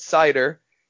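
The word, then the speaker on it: Seider: I I I wasn't sure uh, which way was the proper pronunciation, and so uh, you know when Steve Yzerman called his name, he said cider,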